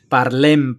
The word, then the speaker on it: parlem: Dicho lo cual, pues em, parlem, parlem de la película.